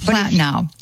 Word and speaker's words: Platinow: Her last name, very difficult to pronounce. Poletino? – Yeah, it's Platinow.